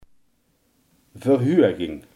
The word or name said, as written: verhoging